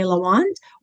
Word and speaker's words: LaWand: So maybe if you just send me an email on social media, so Instagram, LinkedIn, LinkedIn, Facebook, it's just my name, Faye LaWand, or shoot me an email and I'll add you to my list.